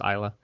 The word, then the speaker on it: Isla: Isla Fisher, pronounced Isla.